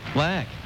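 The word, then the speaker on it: Laak: Or Lock. How do you pronounce it? – I think it's Lack. – Lack.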